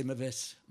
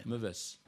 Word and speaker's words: Meves: C'est Meves. – Oui. Bon, si on dit Mio, ça sera anglais, oui.